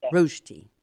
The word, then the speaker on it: rösti: And the Swiss rösti, I believe that's spelled R-O-E-S-T-I, but pronounced rösti. – Yes. – Am I saying that right? – Yeah, it has in Switzerland, it has an umlaut, two little points on the O instead of the E.